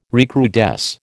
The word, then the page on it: recrudesce: American English